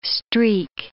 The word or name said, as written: streak